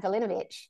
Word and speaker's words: Vulinovich: Yeah, so imagine having a surname like Vulinovich.